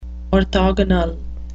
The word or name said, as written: orthogonal